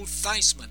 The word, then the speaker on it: Theismann: Notre Dame and Washington Redskins quarterback Joe Theismann, rhymes with Heisman, is 77.